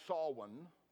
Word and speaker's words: Samhain: Now, this word, it's spelled like it should be pronounced Samhain or Samhain, but it's really pronounced Samhain.